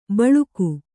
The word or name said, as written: ಬಳುಕು